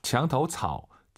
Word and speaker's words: 墙头草: 墙头草. 草. 삼성이 마지막 글자지만 웬만하면 올리지 마세요. 장타오 차오 하지 마시고 토가 아니라 오유는 어우예요. 장타오 차오, 장타오 차오 이렇게 빠르게 발음하시면 좋을 것 같아요.